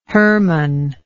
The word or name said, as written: Hermon